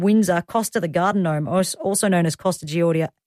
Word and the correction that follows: Costa Georgiadis: I can never say his name, Costa Georgiadis, from Gardening Australia.